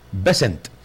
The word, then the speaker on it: Bessent: Asimismo, se conoció que Trump estuvo acompañado por el secretario de Estado de Estados Unidos, Marco Rubio, el secretario del Tesoro, Scott Betz, así se pronuncia, es Scott Bessent,